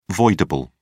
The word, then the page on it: voidable: British English